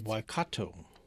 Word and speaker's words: Waikato: And it's just the it's, Waikato.